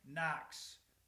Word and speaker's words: Knox: Well, Nebraska ended up that year beating Colorado, Minnesota, and Knox. The K is silent, and the Prairie Fire.